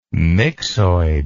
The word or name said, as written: myxoid